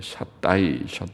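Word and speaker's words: 샤다이 (שדי): 보통은 전능하면은 전능하면은 우리가 샤다이 히브리 말로 샤다이라고 발음하는데 샤다이 엘 샤다이 전능하신 하나님.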